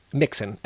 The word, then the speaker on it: Mixon: Is it Kitty Noxon? Kitty Mixon? Kitty Moxon? – Mixon. M-I-X-O-N.